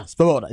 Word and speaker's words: Svoboda: I just think if Texas is able to get him in third and long, that's how, was it Svoboda? Svoboda? Yeah. I can't say his name correctly. I apologize. Svoboda. Yeah, Svoboda. There you go.